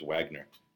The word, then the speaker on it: Wagner: I think it's Wagner. It's pronounced Wagner.